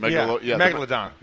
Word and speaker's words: Megalodon: Did I get it right? – Megalodon. – Megalodon. Okay. – Megalodon. – Yeah. I had the wrong emphasis on the wrong syllable.